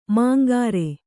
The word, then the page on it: ಮಂಗರೆ: mangare